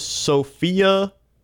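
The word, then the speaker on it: Sofia: Sophia? Not pronounced like the name.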